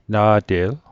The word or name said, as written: na'te'l